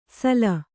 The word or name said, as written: ಸಲ